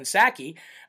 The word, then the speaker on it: Psaki: circle back Jen Psaki. The P is silent, like in President Biden.